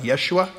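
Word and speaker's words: Yeshua (ישוע): Jesus' name, they probably didn't say it like Jesus. It sounded more like Yeshua.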